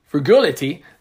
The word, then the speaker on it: frugality: It was a year full of frugility, frugility, frugality.